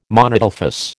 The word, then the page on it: monadelphous: American English